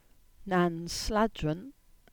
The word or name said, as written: Nansladron